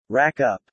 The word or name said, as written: Rack up